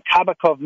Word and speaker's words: Kabakov: Joined by Miriam Kabakov.